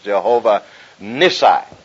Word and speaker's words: Jehovah Nissi: And Moses built an altar and called the name of it Jehovah Nissi. Looks like a long I. Short I first and a long I next. Jehovah Nissi.